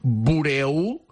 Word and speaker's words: Bureau-Ballet: Bueno, ya podéis googlear, si buscáis Buró Ballet, se pronuncia Buró Ballet, realmente si lo buscáis en Google es en francés, si me permitís que haga un poco el gañán diciéndolo, sería Bureau-Ballet.